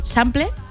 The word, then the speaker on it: sample: Y aquí en los coros de la versión que os he puesto, por debajo está el sample, uh-huh. ¿Lo he dicho bien? – Sí, sí.